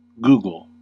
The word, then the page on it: googol: American English